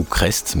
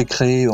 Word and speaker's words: Crest: Alors, euh, la boîte en métal, l'association qui est basée dans la Drôme, d'ailleurs plus précisément à Cré, ou Crest, mais je, je sais qu'on prononce Cré.